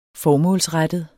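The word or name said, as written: FORMÅLSRETTET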